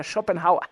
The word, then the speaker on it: Schopenhauer: Así es como se pronuncia mi querido Pablo Peralta, Schopenhauer.